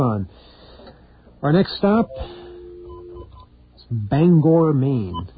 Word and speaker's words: Bangor: Yes, this is Bangor.